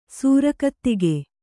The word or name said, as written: ಸರಕುದೆಗೆ